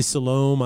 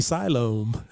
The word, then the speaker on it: Siloam: You say Siloam, I say Siloam.